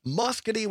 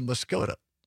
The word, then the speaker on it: Muscoda: Some people will say Muscoda, which is incorrect. – It's spelled like that. – It is not correct. It is Muscogee.